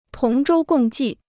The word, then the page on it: 同舟共济: tóng zhōu gòng jì